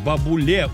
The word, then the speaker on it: бабуле: Нас там ждет бабуле. Ударение именно на «е».